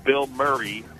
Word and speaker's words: Bilmuri: And then Sunday night, now bear with me here, the name of this band is called Bill Murray, but it's not our Bill Murray. It's a new rock band. The show's completely sold out. It's spelled B-I-L-M-U-R-I. Not Bill Murray that we know.